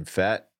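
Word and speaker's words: Pfaadt: Is it Fat? – Brandon. – Gray is fourth. – How do you say that last name? Fat? – Yeah. – Really? – Yeah. – P-F-A-A-D-T. I've never seen a name like that before.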